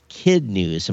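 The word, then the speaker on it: Kidnuz: Yeah, it was Kid News or Kid Nuz or something like that, because I was pronouncing it as it's spelled, KADNUZ, but it's Kid News.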